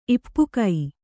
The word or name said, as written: ಇಪ್ಪುಕೈ